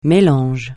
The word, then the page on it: mélange: francais